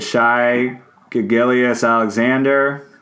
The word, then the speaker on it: Shai Gilgeous-Alexander: They drafted Shai Gilgeous-Alexander, if that's how you pronounce it.